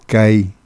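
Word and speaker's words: kay: La consonante K se pronuncia con la lengua, la punta de la lengua y el paladar. Kai.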